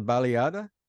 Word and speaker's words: baleada: is called a, how do you, it's called a baleada.